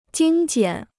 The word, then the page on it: 精简: jīng jiǎn